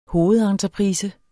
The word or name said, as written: hovedentreprise